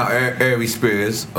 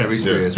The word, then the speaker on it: Ari Spears: So we see just now, Ari Spears, I said his name right? – Yeah. – Ari Spears.